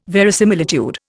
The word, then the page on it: verisimilitude: Indian